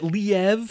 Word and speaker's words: Liev: But Liev is kind of this... Is it Liev? How do you pronounce it? I always say Liev. – I always just pronounce it Liev, so we can say Liev.